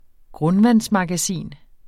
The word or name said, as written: grundvandsmagasin